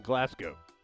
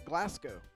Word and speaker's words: Glasgow: And he says, by the way, listening to you guys pronounce Glasgow is, hilarious. Ignore the W in Glasgow.